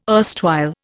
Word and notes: erstwhile: Indian

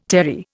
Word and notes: terry: Indian

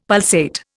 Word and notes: pulsate: Indian

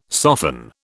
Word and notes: soften: British English

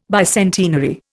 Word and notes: bicentenary: Indian

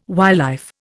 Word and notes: wildlife: Indian

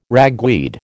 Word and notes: ragweed: American English

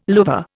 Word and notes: louvre: Indian

